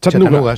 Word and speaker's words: Chattanooga: ¿Se pronuncia así en Chattanooga? – Chattanooga. Sí, City.